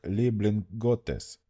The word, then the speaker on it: Леблинготес: «На моем родном языке твое имя произносится как Леблинготес, что означает «любимец бога».